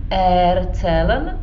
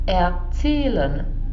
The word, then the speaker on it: erzählen: Nebo není to erzählen, ale erzählen, jo, důraz je až na tom zählen.